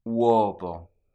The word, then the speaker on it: uovo: Uovo.